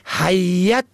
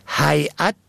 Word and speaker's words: هیئت: و از آن هم جالب این که حرف یا را مشدد ساخته حیت می خوانند، حیت تلفظ می کنند، که قطعا نادرست است، باید حیعت تلفظ شود.